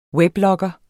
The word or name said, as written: weblogger